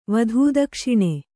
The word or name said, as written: ವಧೂದಕ್ಷಿಣೆ